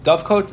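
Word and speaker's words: dovecote: When the person has a, it's called a dove kit or a shevach. It's a big, you know, how you pronounce it? – Dove coat. – Thank you. Dove coat.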